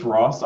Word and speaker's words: Ross: It's Ross, R-O-S-S.